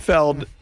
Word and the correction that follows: Pinfield: man, I just went to his... – Pinfield. – A Pinfield. I went to his Wikipedia. – I always call him Pinfeld. – That could be wrong. – It's spelled... – It is spelled Field. – It's spelled Field. – Oh, okay.